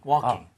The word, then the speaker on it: walking: Work, ing, working. – Walking, walking.